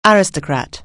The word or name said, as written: aristocrat